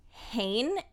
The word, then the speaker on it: Hoehn: So Brooke Cruz told her boyfriend, William Hain, and I'm going to pause for a second just to spell his last name, H-O-E-H-N. And according to all of the news video clips I watched, it's pronounced Hain. – That doesn't add up. – I know, but I watched multiple from different stations and they all said Hain.